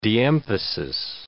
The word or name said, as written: de-emphasis